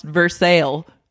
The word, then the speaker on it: Versailles: Someone else told me that it's pronounced Versailles. That's how they pronounce it there.